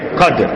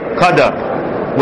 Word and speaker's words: Qadr: So you say Qadr. Alright? But you can also say Qadr with the Dal, with the Fetha on the Dal. So it's pronounced both ways, but they have different meanings.